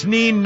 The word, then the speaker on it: Nein: It's neen nine with an I-E. – How is it spelled? – It's spelled N-E-I-N. Which is German for no.